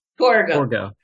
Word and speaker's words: Torgo: And I have to say, Targo. – Your turn to mispronounce. – Is that right? Torgo or Targo? – Torgo. – Torgo. Mm-hmm.